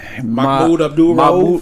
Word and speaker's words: Mahmoud Abdul-Rauf: So the former player, Mahmoud Abdul-Rauf.